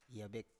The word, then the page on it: (ya)bek: Biak